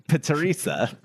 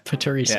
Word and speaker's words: Paterissa: Teresa with a P at the beginning. How do you... Paterisa. Yeah, there you go. – Paterisa.